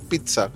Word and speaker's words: pizza: Como es pizza. No es pizza ni pizza. Es pizza. Con T. Pizza se pronuncia. La doble Z se pronuncia TS.